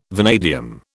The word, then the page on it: vanadium: British English